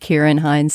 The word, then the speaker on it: Ciaran Hinds: I'm getting a word from our producer, Daniel Schrader, that it's pronounced Ciaran Hinds.